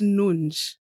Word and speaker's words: Nunes: It's pronounced Nunes.